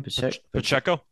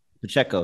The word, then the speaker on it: Pacheco: Pacheco. – Pacheco, yeah.